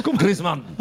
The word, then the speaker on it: Griezmann: En Espagne, c'est comme ça que ça se prononce. Griezmann.